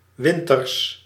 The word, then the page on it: hivernal: France; [i.vɛʁ.nal]